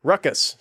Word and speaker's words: ruckus: But the implication is how do you say that word? Roxious? Really, ruckus.